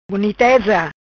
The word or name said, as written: Buniteza